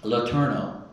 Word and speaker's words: Letourneau: Io sono cresciuto nel Texas orientale e lì c'era un signore che si chiama Letourneau, e senz'altro un nome in francese, ma lo pronuncio come abbiamo fatto noi.